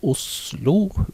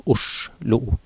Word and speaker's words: Oslo: Om du skal uttale Oslo med tungespiss L. Men S. Eller du skal bruke S-lyden, Oslo. Altså det er jo Os som er utgangspunktet der, så det er historisk korrekt, det vil være å si Oslo.